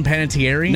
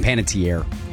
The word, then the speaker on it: Panettiere: Am I pronouncing her name right? Hayden Panettiere? – No, it's Hayden Panettiere. – Tier. Dang it.